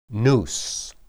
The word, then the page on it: nus: hebrew